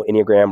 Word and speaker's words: Enneagram: I'm an Enneagram type one, which, don't worry, I know Enneagram rhymes with pentagram, so it's obviously of the devil.